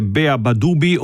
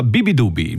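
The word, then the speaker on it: beabadoobee: Vi ho annunciato che si tratta di Pylon, il nuovo disco di questo artista, il cui nome si scrive Beabadubi. Si potrebbe leggere Beabadubi o Beabadubi, ma no, lei lo pronuncia Bibidubi.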